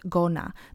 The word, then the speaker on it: gonna: Don't pronounce it gonna though. There is no o sound gonna. There is a schwa sound instead. So it should sound gonna, gonna.